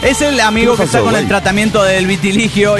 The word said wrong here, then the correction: vitiligo: y está muy mal con el vitiligo. Perdón, sí, no sé si siempre lo pronuncio bien.